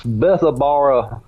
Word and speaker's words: Bethabara: Since our last show that we did on here a couple weeks ago was about cemeteries, I'm going to say it wrong because when I when you said it, I saw I sound like you were saying a bar, but it's Bath Bath Bathabra Cemetery. Is that is that how you pronounce it? – Uh, it's Bethabara Cemetery.